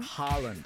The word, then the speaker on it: Haaland: Farland? Is that how you say his name? – Haaland.